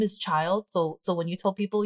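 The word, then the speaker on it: Childe: Child? – Child.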